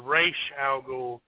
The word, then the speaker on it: Ra's al Ghul: Okay, in the film they pronounce it like it's spelled, which is Ra's al Ghul, which was played by Liam Neeson, which was perfect, by the way. In the comic, the S is pronounced like an accent. It's Ra's, like R-A-S-H. – Okay. – So it's Ra's al Ghul.